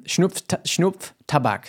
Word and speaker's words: Schnupftabak: Schnupftabak. Thank you. That's how to pronounce it.